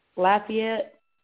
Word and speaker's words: Lafayette: Now, explain to us, is Lafayette a small area? Is this a rural area? Is it a small community? What is it like in this area? – It's actually pronounced Lafayette, Tennessee.